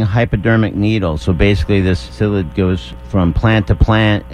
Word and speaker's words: psyllid: The psyllid? – It's a psyllid.